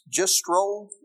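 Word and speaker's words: Jastrow: I like this quote from Robert, I think it's pronounced Gistrol,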